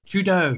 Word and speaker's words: Trudeau: Trudeau, sorry.